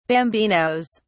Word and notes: bambinos: British English

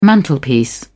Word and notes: mantelpiece: British English

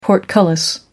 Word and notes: portcullis: American English

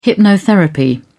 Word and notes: hypnotherapy: British English

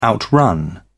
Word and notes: outrun: British English

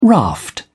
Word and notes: raft: British English

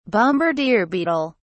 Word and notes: bombardier beetle: British English

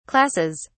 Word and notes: classes: British English

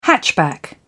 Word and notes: hatchback: British English

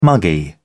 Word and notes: muggy: British English

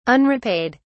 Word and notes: unrepaid: British English

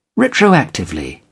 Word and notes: retroactively: British English

